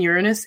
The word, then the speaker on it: Uranus: Am I saying it right? – Almost. If someone has Mars trine Uranus in their natal chart, Tuesday is going to be a wonderful day.